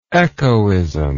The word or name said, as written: echoism